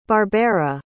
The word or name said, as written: barbera